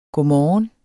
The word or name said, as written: godmorgen